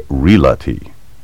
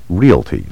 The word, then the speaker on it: realty: Realtor puts the sound of the L before the A, as though the word were spelled R-E-L-A-T-O-R. The proper pronunciation sounds like the word real, followed by ter, realtor. You should also take care to pronounce the word realty correctly. Don't stick the L sound where it doesn't belong and say realty. The word should be pronounced realty, like real plus T.